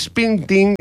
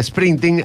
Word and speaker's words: Springsteen: También Bruce Springsteen, no lo dije bien, Springsteen, ahora sí, nada, Julio bien.